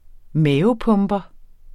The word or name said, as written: mavepumper